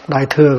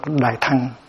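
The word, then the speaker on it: đại thặng: Chứ thừa có khi đọc là thằng, đại thẳng, đại thừa có khi là đại thẳng, ở trong này đọc đại thẳng.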